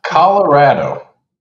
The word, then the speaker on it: Colorado: Colorado. – Andy. – Call a goddamn ratto. – Call a fucking ratto. – No, it's pronounced Colorado, you potty mouthers.